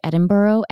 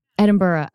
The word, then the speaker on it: Edinburgh: You know, I saw that it took you to, and I still pronounce it wrong. I think Edinburgh. Yeah. – Edinburgh. Okay. I always walk the line of pronouncing it wrong,